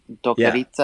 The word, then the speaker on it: doktorica: Oh, how do you pronounce that C sound? – Oh, itza. Doktorica. Yeah.